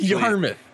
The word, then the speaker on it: Yarmouth: That's how it's actually. – It's Yarmouth. – Yeah, that's actually how it's pronounced. You're wrong.